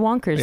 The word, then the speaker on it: wankers: Yeah, and then sometimes it's spelt obviously the way that it is said, but when they say it, it doesn't sound as aggressive, like wankers. And They said wonkers.